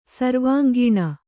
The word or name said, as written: ಸರ್ವಾಂಗೀಣ